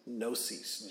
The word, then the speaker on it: gnosis: But the Greek word for knowledge to know is gnosis, G-N-O-S-I-S, gnosis. The G is silent.